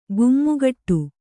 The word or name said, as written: ಗುಮ್ಮುಗಟ್ಟು